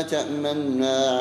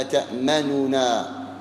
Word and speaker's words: تأمنا: أصل الكلمة تأمننا، فلا تقرأ النون مضمومة نو، وإنما تسكنها وتظهر حركة الضمة وهي ضم الشفتين، فتقرأ قالوا يا أبانا ما لك لا تأمنا على يوسف، ما لك لا تأمنا على يوسف.